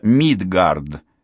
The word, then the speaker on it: Мидгард: Это Мидгард, ударение на первом слоге.